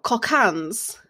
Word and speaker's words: Cockaigne: It's spelled, so just to let you guys know, it's spelled C-O-C-K-A-I-G-N-E. – Yeah. I YouTubed it before this and she did say it was like cocaine.